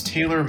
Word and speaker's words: Taylor: My name is Taylor Mowdy. I'll spell it T-A-Y-L-O-R, Mowdy, M-O-U-D-Y.